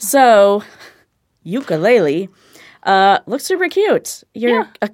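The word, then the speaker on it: Yooka-Laylee: Yeah, called Lukulele. – Ukulele.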